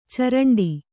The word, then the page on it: ಚಿರಂಟಿ: ciraṇṭi